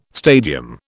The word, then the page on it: stadium: British English